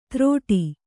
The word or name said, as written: ತ್ರೋಟಿ